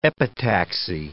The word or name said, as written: epitaxy